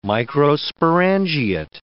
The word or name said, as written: microsporangiate